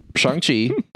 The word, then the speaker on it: Shang-Chi: It's Shang-Chi. I also keep saying it wrong. Shang-Chang. Sean. – It's Shang-Chi. – They addressed Shang-Chi. It in the movie, Shane. I don't know. – Shang-Chi. Because I've been saying Shang-Chi for forever, okay? – Stop being so white.